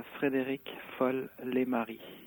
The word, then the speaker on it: Frédéric Folle-Lemarié: It's Frédéric Folle-Lemarié. – Okay. – That's the French way of saying it. – Yes. – You could say in English it's more like Frederick Yeah.